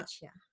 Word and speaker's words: Taccia: So basically it's they just came out with the, I believe it's pronounced Tachia, or it's that, is it a branch of Sailor Inc. or T-A-C-C-I-A. – Yeah. – Tachia.